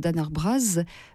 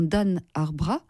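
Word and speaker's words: Dan Ar Braz: Plus tard, lors de ses tournées dans les pays anglo-saxons, il se rend compte que le S n'est pas prononcé et ce Dan Arbraz ne lui plaît décidément pas. Il décide donc de changer le S en Z et cela deviendra Dan Arbraz, sans autre.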